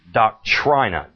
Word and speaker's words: doctrina: Some would pronounce that doctrina. Doctrina. D-O-C-T-R-I-N-A.